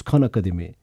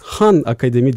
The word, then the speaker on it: Khan Akademi: Kan Akademi. Doğru biz Kan Akademi kan, diyoruz. Tamam. E, Arapça bilen büyüklerimiz Han Akademi diye de telaffuz ediyorlar. Fakat dünya genelinde sanırım Kan Akademi olarak tanınıyoruz.